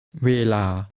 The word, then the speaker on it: เวลา: First, here's how to say time, which is pronounced with two middle tones. Listen and repeat. – เวลาลาเวเวลา And here's takes time.